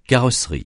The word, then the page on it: carrosserie: francais